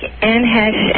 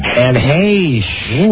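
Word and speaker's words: Anne Heche: I've been told I look like Anne Hesh and Charlize Theron. – Anne Hesh? – Anne Hesh. – Ooh. – Hesh rhymes with Gaish.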